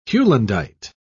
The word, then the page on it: heulandite: British English